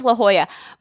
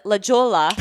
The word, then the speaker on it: La Jolla: Has something to do with La Jolla. My husband pronounces it La Jolla or whatever. – Oh, totally calling you out. – Yeah, but it is La Jolla.